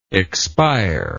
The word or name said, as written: expirer